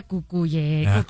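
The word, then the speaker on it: Cucurella: No, pero se pronuncia Cucuyella. Ya no puedo. Cucureya.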